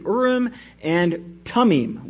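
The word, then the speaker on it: Urim and Thummim: All right, looking at verse 6, the Urim is speaking of the Urim and Tummim, which I believe is the correct way to pronounce that.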